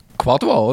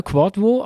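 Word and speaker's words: Kwadwo: Quadvo, oder? – Genau, Quadvo. Also die W sind so kurze U, hat mir der Ralf geschrieben.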